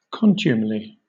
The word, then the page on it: contumely: British English